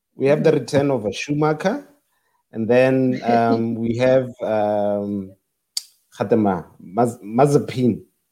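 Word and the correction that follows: Nikita Mazepin: I don't know how to pronounce Nikita. – Nikita, yes.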